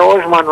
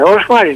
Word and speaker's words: Όσμαν: Γιατί το λέει συνέχεια Όσμαν, Όσμαν. Δεν είναι Όσμαν, ρε παιδιά. Όσμαν είναι. Και πολλοί όλοι το λένε. Τα τουρκικά, οι τουρκικές λέξεις στη λίγουσα. – Ναι. Τονίζονται στη λίγουσα.